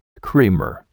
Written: creamer

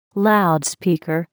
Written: loudspeaker

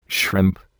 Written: shrimp

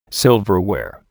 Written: silverware

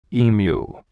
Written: emu

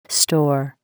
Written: store

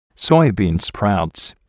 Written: soybean sprouts

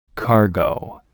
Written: cargo

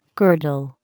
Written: girdle

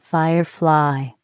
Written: firefly

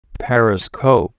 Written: periscope

